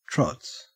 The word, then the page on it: trots: Australian English